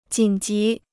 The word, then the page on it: 紧急: jǐn jí